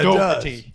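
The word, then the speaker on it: Daugherty: It's D-A-U-G-H-E-R-T-Y. – I was literally going to guess that. – It's got a little bit of the Irish in it, so yeah, it's Doherty. Sometimes spelled with O and a variety of other things.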